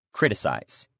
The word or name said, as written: criticize